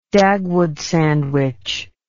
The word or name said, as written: Dagwood sandwich